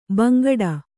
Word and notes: ಬಾಂಗಡಿ: Kannada

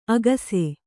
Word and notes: ಅಗಸೆ: agase

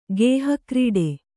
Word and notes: ಗೇಹಕ್ರೀಡೆ: Kannada

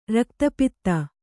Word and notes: ರಕ್ತಪಿತ್ತ: rakta pitta

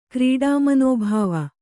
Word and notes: ಕ್ರೀಡಾಮನೋಭಾವ: krīḍa manōbhāva

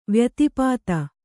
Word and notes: ವ್ಯತಿಪಾತ: vyatipāta

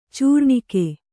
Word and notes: ಚೂರ್ಣಿಕೆ: cūrṇike

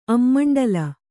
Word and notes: ಅಮ್ಮಂಡ¯: ammaṇḍala

